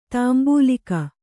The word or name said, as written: ತಾಂಬೂಲಿಗ